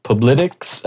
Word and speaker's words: Publitics: To make and how do you pronounce, is it Publitics? How do you pronounce that? – I think I had that one. – That's fine. Yeah, no, you pretty much nailed it.